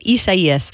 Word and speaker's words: Isaias: And yes, I had to look up how to say it. Isaias, that is the name of, it's just a tropical storm right now.